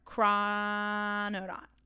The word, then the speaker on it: Chrononaut: How do you say it? – Chrononaut. – Chrononaut. – Astronaut. Chrononaut.